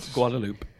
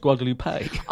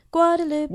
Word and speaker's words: Guadeloupe: Guadeloupe. – A. Guadeloupe. – All right, so can we just nail this? So Steph genuinely thinks that Guadeloupe is pronounced Guadalupe.